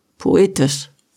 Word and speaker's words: poetisch: Das klingt doch total pötisch, oder? Pötisch. Ja, pötisch. Du meinst poetisch. Ah, ach so, äh, äh, so spricht man das aus.